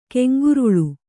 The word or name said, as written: ಕೆಂಗುರುಳು